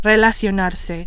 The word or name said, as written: relacionarse